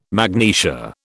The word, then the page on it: magnesia: British English